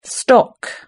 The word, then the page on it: stock: British English